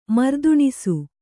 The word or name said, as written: ಮರ್ದುಣಿಸು